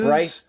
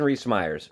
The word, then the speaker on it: Rhys-Meyers: Rice? No. – Jonathan Rhys-Meyers? – Jonathan Rhys-Meyers. – Rhys-Meyers, yeah. Yeah, he's a good-looking dude.